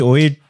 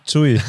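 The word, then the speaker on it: Tsui: Nee, nicht Ui, Zui.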